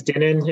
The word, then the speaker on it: Dinan: My name's Dinan. It rhymes with linen.